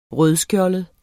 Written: rødskjoldet

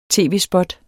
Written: tv-spot

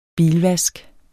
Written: bilvask

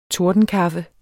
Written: tordenkaffe

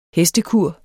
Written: hestekur

